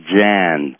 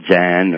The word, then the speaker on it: Dzyan: And this was called the Book of Zan, spelled D-Z-Y-A-N. And I'm not sure of the pronunciation since I learned these things myself. And if I was subject to a more formal theosophical education, they may pronounce that term differently. But I'm used to saying the Book of Zan, D-Z-Y-A-N.